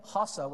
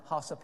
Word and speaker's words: Xhosa: I say Xhosa with the click because they're from South Africa and the Xhosa people speak with clicks.